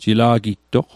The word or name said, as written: jila'gittoq